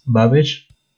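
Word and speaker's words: Bhavesh: Es Babesh, B-H-A-V-E-S-H. E Yoshi es J-O-S-H-I.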